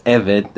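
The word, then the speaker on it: Eved (עבד): The word for slave that's used throughout the Tanakh is Eved, that's spelled Ayin Vav Dalet.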